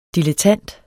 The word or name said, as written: dilettant